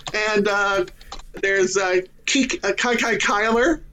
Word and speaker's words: Kyler: And to know how to pronounce his first name, because it's not Kiki, it's Kai Kai.